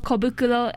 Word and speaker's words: Kobukuro: Okay, so Kobukuro, it's spelled K O B U K U R O.